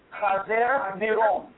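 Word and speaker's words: Chazer Niron (קיסר נרון): If you want to say his name in Hebrew, Phil, it would be Chazer Niron.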